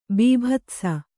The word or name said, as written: ಬೀಭತ್ಸ